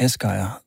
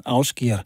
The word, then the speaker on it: Ásgeir: Jeg håber ikke, det gik ud over den samlede oplevelse Ásgeir her, som jeg kom til at kalde Ásgeir, da jeg først lærte ham at kende. Men siden har jeg mødt en islænding, som siger, at man skal altså udtale hans navn Ásgeir. Så det prøver jeg.